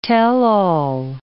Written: tell-all

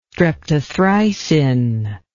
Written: streptothricin

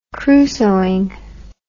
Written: crusoeing

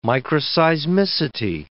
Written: microseismicity

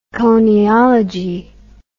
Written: coniology

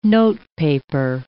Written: notepaper

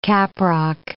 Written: caprock

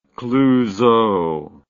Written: clouseau

